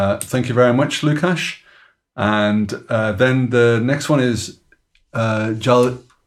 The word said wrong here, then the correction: Jaihind: Reddy. Oh, Jaihind Reddy. Maybe as you know, Jaihind Reddy. Huh? Yeah, Jaihind Reddy.